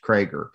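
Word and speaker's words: Kroeger: In her books and her articles, she's usually referred to as Catherine Clark Krager, and it looks like Kroger, but it's pronounced Krager.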